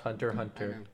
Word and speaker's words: Hunter x Hunter: It's pronounced Hunter Hunter. – Oh, it's pronounced Hunter Hunter? Yeah.